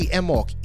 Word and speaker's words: Emawk: I say Emok. E-M-A-W-K.